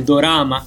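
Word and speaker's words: dorama (ドラマ): Devo dire sì che io ho visto di recente questo telefilm questo drama o con eh, pronuncia giapponese dorama, quindi dal vero di Mimi,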